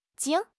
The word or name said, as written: 茎